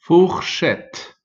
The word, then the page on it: fourchette: son u; ou